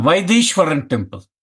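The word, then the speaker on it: Vaitheeswaran: Did I say it right? It's the Vathahisawaran temple? – The Vaideshwaran temple.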